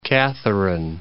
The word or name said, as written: Mount Catherine